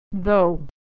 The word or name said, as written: though